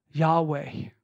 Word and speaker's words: Yahweh: Whenever you see Lord capitalized in your Bible, it is the Hebrew consonants, YHWH, which we believe is best pronounced Yahweh.